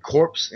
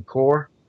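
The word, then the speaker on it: corps: We were talking about the receiving core, and I said corpse, and it's not supposed to be receiving corpse. It's receiving core.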